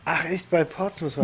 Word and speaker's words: Portsmouth: Portsmouth. – Portsmouth, genau.